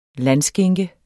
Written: landskinke

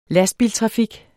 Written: lastbiltrafik

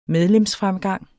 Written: medlemsfremgang

